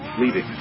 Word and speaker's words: Levy: It's actually sort of with a hard E, like E, you know. – Levy.